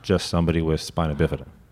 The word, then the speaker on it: spina bifida: Thank you for pronouncing that right. – How do they say it? – Oh, man. I get it all. – Spinal bifida? – Yeah. Spinal bif.